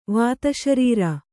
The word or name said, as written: ವಾತಶರೀರಿ